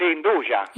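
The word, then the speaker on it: 'nduja: Voi la pronunciate Anduja, quindi? – Sì, Anduja, dal francese deriva.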